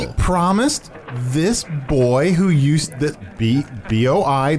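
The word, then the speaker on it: Boi: Arnie's and, pronounced boy.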